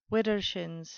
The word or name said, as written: widdershins